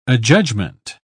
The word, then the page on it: adjudgment: British English